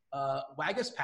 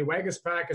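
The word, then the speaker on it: Waguespack: I actually went before the show and I tried to figure out how to say this dude's name from Toronto. Jacob Wagaspak, I believe is how we pronounce that, drawing the starts.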